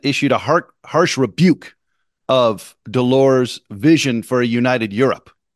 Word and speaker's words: Delors: And the headline summarizing Thatcher's speech read, Up Yours Delors, which of course has a silent S at the end of it. So it looks like Up Yours Delors.